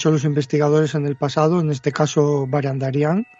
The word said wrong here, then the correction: Barandiarán: Barandiarán, que siempre lo digo mal.